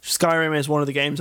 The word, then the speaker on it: Skyrim: Scrim. – Scrim Hudder Remix. – Let's call it the right name, because people think that's how we actually pronounce it. Skyrim.